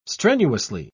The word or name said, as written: strenuously